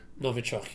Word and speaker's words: Novichok: who apparently came over and they used the Novichok. Is that the correct way you pronounce it? – Novichok, yeah.